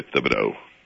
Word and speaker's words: Thibodeau: In French, there is no TH, but I say Thibodeau.